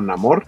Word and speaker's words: Namor: Pero cada, quien lo puede de distinta forma, pero él sí dice Namor. – Ajá, pero mi, o sea, digamos que mi bronca de continuidad en la película es que él se presenta como Namor y luego tanto Ramonda como Shuri le dicen Namor.